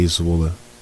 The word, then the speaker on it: Zwolle: CSA Zwolle.